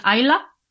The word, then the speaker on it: Islay: eh, se escribe Islay, para los que lo veáis es que tiene una etiqueta y los gaélicos lo pronuncian Ayla, curiosamente,